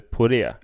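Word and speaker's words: Poret: Porrett. – Jag inledde ju med att säga Emilia De Porrett. Ja. För den franska stavningsregeln föreskriver ju att T inte ska uttalas.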